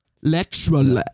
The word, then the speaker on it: literally: Also das Valley Girl hat noch geschrieben, mir gefällt, wie Noe das Wort Literali ausspricht, irgendwo in der Mitte von der ersten Freundinnenfolge. Weil ich sie anscheinend immer falsch ausspreche. Aber ich ja, wahrscheinlich wir beide. – Ich lasse jetzt so der Neue zu, wie sie es sagt. Literally. Ich glaube, wir müssen es so sagen.